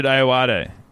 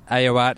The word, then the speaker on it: Ayoade: I don't know how to say his last name. – Richard Ayoade. – Ayoade. That feels like too many syllables for the number of letters there.